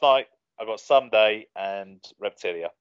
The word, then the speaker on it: Reptilia: Is it Reptilia? Reptilia? Is that how you say it? – Yep.